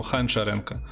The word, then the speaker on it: Гончаренко: Например, Гончаренко, Гончаренко. Ну потому что э, белорусское произношение. Ха, Гончаренко. У нас же не Г, а Г, Г, как в украинском, а Г очень похоже на Х, поэтому Ханчаренко. Вот.